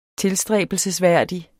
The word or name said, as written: tilstræbelsesværdig